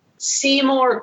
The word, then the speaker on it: Seymour: Baj is the first location that may be explored by the player, is critical to understanding the emotional development of Maester Saimor Guado, one of the game's major antagonists. It was a great island city, Guado? – Seymour Guado is his actual pronunciation.